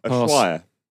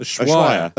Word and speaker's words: Ushuaia: Pronounce that. Ushaya? I think it's in Ibiza. – Nah. – Ushuaia. – What? – Ushuaia. – Ushuaia.